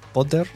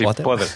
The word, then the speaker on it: Podder: Y por suerte han venido eh, a nuestro rescate empresas como la que tenemos hoy, eh, que se pronuncia así, ¿no? Potter. – Sí, sí, sí, Potter.